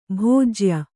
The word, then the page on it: ಭೋಜ್ಯ: bhōjya